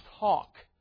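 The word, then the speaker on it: hough: The word you're going to come across here in your King James in Joshua chapter 11, verse number 6, H-O-U-G-H. That's a word we don't use in our English language, but that word is pronounced hawk, like a ham hawk, okay?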